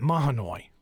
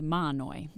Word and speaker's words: Mahanoy: First of all, the pronunciation there for those of us who are not from, I've always pronounced it Mahanoy. – Well, locals say Mahanoy, a silent H. And it's tricky. – It is tricky, Mahanoy. And some people even call it Mahoney, but that's not standard.